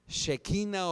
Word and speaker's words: Shekinah: Hay muchas versiones cómo se pronuncia, pero la mayoría le dice la Shekinah o Shekinah gloria de Dios.